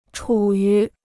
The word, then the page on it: 处于: chǔ yú